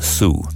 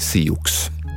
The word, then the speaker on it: Sioux: Och det där Nadowessu var ett långt ord att uttala och då tog fransmännen fasta bara på den sista stavelsen, Sioux, och gav den fransk stavning, Sioux.